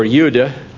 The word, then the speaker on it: Jude: But the importance about Jude or Yuda, the J would be pronounced as a Y in Hebrew and lots of other languages, except Spanish-based languages. And it would be Huda, I guess, there.